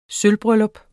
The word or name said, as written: sølvbryllup